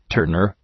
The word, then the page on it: turner: ‘tɜ:rnər